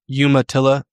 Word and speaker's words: Umatilla: Umatilla Indian Reservation, Northeast Oregon, from like the Blues to the Basin of the hills.